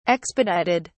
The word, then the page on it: expedited: British English